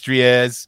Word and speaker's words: Dries: Couple recent videos lately with Dres Mertens out there, but officially I want to pronounce it Dries. I know it's Dries. Dries your eyes, just for rhyming purposes.